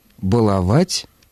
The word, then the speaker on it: баловать: Глагол «баловать» требует ударения на третьем слоге. И, соответственно, если мы употребляем его в настоящем времени, где суффикс претерпевает изменения, ударение падает на суффикс. Балуешь, балуем, балуют и так далее.